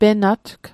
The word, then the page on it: penatg: Mi'gmaq